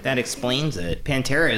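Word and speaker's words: Panera: First of all, it's pronounced Pantera.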